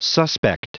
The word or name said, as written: suspect